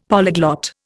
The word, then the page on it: polyglot: Indian